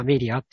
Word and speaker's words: MELIA: なんか、まあ、日本人の方なんですけど、まあ、そういう通称というか、なんか、 MELIA とかで、まあ、メリアって読むような、はい。